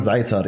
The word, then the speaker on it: Azaitar: Now, obviously, you've had a number of prominent guests, mashallah, you know, namely and recently you had Uthman Azatir, is that how you pronounce it? – Uthman Zaitar, yeah.